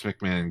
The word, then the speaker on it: McMahon: No, it's McMahon, guys. The H is silent.